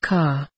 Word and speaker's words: Ka: I come from a planet that goes by the name of Ka, which is pronounced like your letter of the alphabet K, and as it is my home planet.